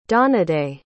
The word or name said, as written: donadei